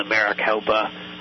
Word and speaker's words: Maricopa: The Maricopa, I'm probably pronouncing that wrong, and I apologize. – No, I think that's right. – Okay, good. The Maricopa